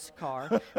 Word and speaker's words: Sychar: So that's pronounced a couple different ways. I'm going with Sychar.